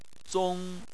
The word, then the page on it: zong: 1